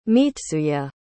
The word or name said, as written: mitsuye